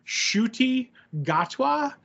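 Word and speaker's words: Ncuti Gatwa: His name is pronounced Shuti Gatwa, so I guess that's his shooty name.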